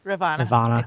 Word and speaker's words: Ravana: The battle challenge, they are giving out I Beat Shiva shirts and Ravana. How do you say it? – Ravana.